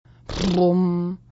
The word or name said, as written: Brumm